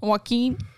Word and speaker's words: Joaquin: How do you say Joaquin in Espanol? – Joaquin. – I guess, yeah. – Joaquin. – Joaquin. – Joaquin Phoenix. – Joaquin Phoenix. – Actually, it's more of a Y.